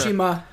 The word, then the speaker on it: Tsushima: Did I say that right? Is that it? Tsushima? – Close enough. – Tsushima.